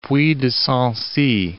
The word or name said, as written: Sancy, Puy de